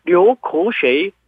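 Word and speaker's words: 류고쉐이 (流口水): 구수, 침이란 얘기인데 그래서 침을 흘리다 하면은 류구수, 중국어로 하면은 류고쉐이.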